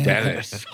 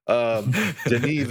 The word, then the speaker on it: Denis: For me, I think the biggest thing, and it's similar to Denis, and you guys actually taught me how to say his name properly. I was calling him Dennis all these years. – Yes, my boy Dennis. – Total Midwestern noob, Denis Villeneuve.